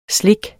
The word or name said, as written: SLIKS